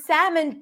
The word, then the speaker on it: salmon: Or actually, the word salmon does have an L in it, right? Because I said there is no L because I was talking about the pronunciation, but I did not say the L sound.